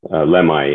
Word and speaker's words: Lemhi: How did you pronounce it? Lemhi or Lemhi? – Lemhi. Yeah.